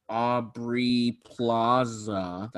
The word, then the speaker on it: Aubrey Plaza: And you know, Aubrey Plaza, that's how you say her name.